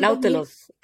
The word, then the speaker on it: Nautilus: Nautilus, creo que le decían. – Sí. – No estoy segura. – Sí, así, así, tal cual como lo pronunciaste.